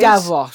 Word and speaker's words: Davros: I think it's, is it Stavros? I think it is. – Davros. – Stavros, see? Can't even pronounce his name right.